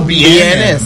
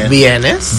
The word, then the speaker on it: vienes: So is the V, V or B? – V. – V, V. – V. – So how do you know when to pronounce B or V? Like there is something? – It pronounces B. Aquera Bienes. – Bienes. – B. So it is a B. – It's B. – It's a B sound.